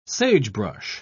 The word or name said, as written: sagebrush